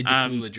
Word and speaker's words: Dacula: And it's also a Christian academy in, I'm not sure if it's Decula or Dacula, Georgia. Probably Decula. – I'd say Decula, Georgia. – But it's spelled like Dracula without the R, so there's kind of a tendency.